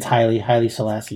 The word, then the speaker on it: Haile Selassie: Haile Selassie? Haile Selassie? – I think it's Haile Selassie, yeah.